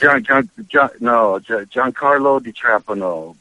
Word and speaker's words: Giancarlo Di Trapano: But, like, first of all, your name, it's Giancarlo Di Trapano. Is that how you pronounce Giancarlo Di Trapano,